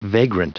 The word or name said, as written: vagrant